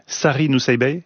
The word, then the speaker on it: Sari Nusseibeh: Sari Nusseibeh, c'est comme ça qu'on prononce. – Oui.